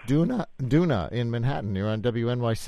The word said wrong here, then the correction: Dunya: Dunya, like sand dune with a on the end. – Okay, Dunya.